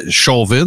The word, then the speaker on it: Chauvin: Ça se prononce définitivement en anglais Chauvin, là, mais c'est Derek Chauvin.